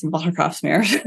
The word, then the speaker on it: Watergraafsmeer: So I live in the east part of the city and it's in a neighborhood called, it's an American, I would pronounce it Watergraafsmeer, but it's Watergraafsmeer.